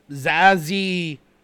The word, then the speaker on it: Zazie: Zazie Beetz. – Zazie Beetz. That's the name. – Z-A-Z-I-E. Zazie. – That's how I would pronounce it. Yeah. – And then her last name is B-E-E-T-Z.